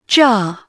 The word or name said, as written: jar